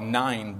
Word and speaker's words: Nain: So now the word Nain, it's actually more pronounced more like nine, but that kind of confuses us. I'm going to keep with the pronunciation of Nain.